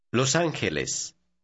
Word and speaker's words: Los Ángeles: Notice the combination of letters G-E is pronounced K. Repeat number 6, looking at that part of it. – Los Ángeles.